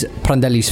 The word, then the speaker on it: Prandelli: To start with, you can hear how the names of Montolivo and Prandelli are meant to be pronounced. – I mean, Riccardo Montolivo is going to be out, and he's Prandelli's favourite.